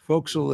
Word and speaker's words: forecastle: Forecastle is F-O-R-E-C-A-S-T-L-E. It's pronounced forecastle.